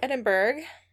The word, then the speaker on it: Edinburgh: But also a scientist from the University of Edinburgh.